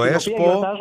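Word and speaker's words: Espoo: Το ΕΣΠΟ, έτσι το λέτε εκεί στη Φιλανδία. Το... – Ακριβώς όπως το προφέρατε.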